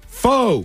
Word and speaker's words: pho: Pho. – Pho. – Pho. – Pho. – Pho. – Pho. Pho. Pronounced as the Vietnamese themselves do it, pho.